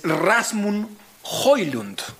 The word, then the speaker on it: Rasmus Højlund: Digamos que una de las grandes apuestas, justamente hablando de fútbol danés, es Rasmus Højlund.